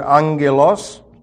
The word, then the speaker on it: angelos: And unto the angel, the word angel, as you remember, is from the Greek word angelos, and double G's in Greek are pronounced ng, N-G.